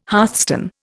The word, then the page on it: hearthstone: Indian